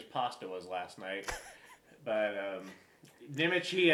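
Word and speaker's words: Nemec: Nimic. – It's N-E-M-E-C.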